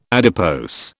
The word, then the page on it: adipose: British English